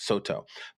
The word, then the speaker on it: Soto: And what happens is that certain characters are pronounced differently. So Cao is pronounced So and Dong is pronounced To. So we get Soto.